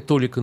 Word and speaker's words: толика: Какая бы многочисленная демонстрация ни шла сегодня в Германии, это все-таки маленькая толика населения. Толика, ну, да. Правильно говорить. Маленькая толика населения.